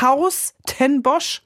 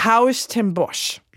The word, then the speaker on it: Huis ten Bosch: Das niederländische Wort Huis ten Bosch in Klammern spricht man gleich aus wie das deutsche Wort Haus. – Ja. – Also Haus ten Bosch heißt es, Chrissy Kahn. Wir müssen nicht mal Huis sagen.